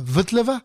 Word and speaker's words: Vltava: Vous connaissez certainement la fameuse Moldau, ce poème symphonique qui parle du fleuve qui traverse Prague, qu'on appelle aujourd'hui la Vltava, pour essayer de le prononcer à la tchèque.